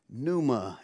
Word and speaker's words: pneuma: Pneuma is spelled P-N-E-U-M-A.